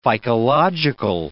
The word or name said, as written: phycological